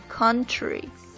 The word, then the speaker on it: country: 因为好像在英语中也有一些单词是这个 O 和 U 拼写的时候会发成这个大口的 L，双元音 L，不过注意在这里是这个尖角啊，所以这个单词应该读作 country。